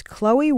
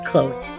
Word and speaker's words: Chloe: It is my birth name, Chloe. My saint's name, Anthony. My maiden name, Wofford. People mispronounce it all the time. They said Chlo, even my teachers. Chlo, Clow, or Clovis.